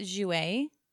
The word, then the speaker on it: Jouer: Is it Jouer or Jouar? – So I pronounce it Jouer. Jouer.